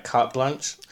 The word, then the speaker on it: carte blanche: Then you have a bit more carte blanche. Am I saying that right? Carte blanche.